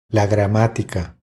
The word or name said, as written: La gramatica